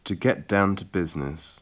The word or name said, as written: to get down to business